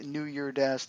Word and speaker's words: New Year Dash: All right, so let's get into talking about actual New Year Dash here, and it is in fact New Year Dash. There is no S, because sometimes I get that question and I'm just like, nope, you don't see an S on it, don't pronounce the S.